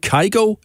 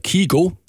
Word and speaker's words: Kygo: Det er på grund af en DJ Kygo, eller Kygo, alt efter hvordan man vælger at udtale det.